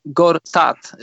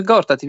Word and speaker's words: Gortat: Zobacz, mówią, oni mówią Gortat, y, wiesz, tak specjalnie akcentują. My mówimy Gortat i wiemy, jak się mówi Gortat.